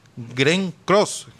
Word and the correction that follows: Glenn Close: Glenn Close,